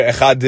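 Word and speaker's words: echad (אחד): But that doesn't mean that you can cut off the chet, meaning you have to say echad, right? Not echad, just to rush through the chet so you can get to the dalet faster. You have to still pronounce it properly.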